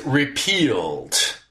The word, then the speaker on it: repealed: So you said repelled when what you need there is repealed.